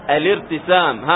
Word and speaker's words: الارتسام: الآن لأنني أبتدئ بها فأنطقها تصير مثل همزة القطع في النطق فقط لأنها عند الابتداء، لكن عند الوصل وارتسام، الارتسام هكذا تذهب همزة الوصل تماما.